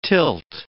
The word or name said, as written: tilt